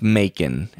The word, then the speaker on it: Macon: I believe his name is pronounced Macon, as in bacon. – Macon.